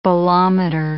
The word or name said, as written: bolometer